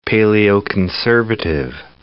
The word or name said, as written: paleoconservative